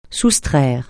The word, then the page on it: soustraire: francais